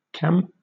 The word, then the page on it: cam: British English